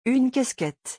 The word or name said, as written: Une casquette